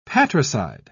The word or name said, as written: patricide